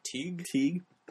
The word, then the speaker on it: Tighe: I think it's super interesting that they cast, I might mispronounce his name, Kevin Tighe? – Teague. Yeah, from Eight Men Out.